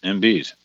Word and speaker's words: Embiid: Embiid.